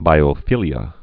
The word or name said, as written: biophilia